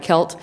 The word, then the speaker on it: CELT: In addition to that, we have the Center for Excellence in Learning and Teaching, which is CELT, and we pronounce that KELT.